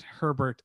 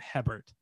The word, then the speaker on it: Hebert: So for the listener at home, my last name is spelled H-E-B-E-R-T. And so the sort of three wrong ones that I get more often than I get correct pronunciations are Hebert, Herbert, and Hebert.